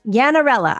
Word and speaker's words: Yannarella: Yannarella.